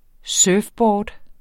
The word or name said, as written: surfboard